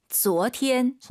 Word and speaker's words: 昨天: 昨天.